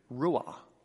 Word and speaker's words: ruah: Now, breath is a Hebrew word, ruah. Ruah. You could spell it R-U-A-H.